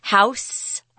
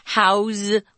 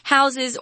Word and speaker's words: house: If you use house as a verb, notice that the pronunciation changes. I'm not saying house with an S. I'm saying house with a Z.